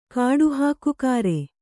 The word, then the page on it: ಕಾಡುಹಾಕುಕಾರೆ: kāḍu hākukāre